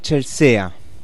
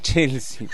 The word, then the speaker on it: Chelsea: Te recuerdo claramente en el Hotel Chelsea. ¿Se pronuncia así? No. Chelsea, perdón.